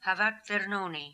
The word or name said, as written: Havatvernoni